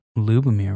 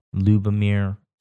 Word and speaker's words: Ljubomir: If that L is silent, it's Jubomir. – Well, it could be Lubomir, right? – L-J-U-B-O-M-I-R. Lubomir Stefanov. Really captivating cinematography.